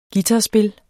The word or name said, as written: guitarspil